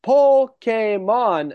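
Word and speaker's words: Pokémon: Now, in case you didn't hear how I said that, it is pronounced Pokemon,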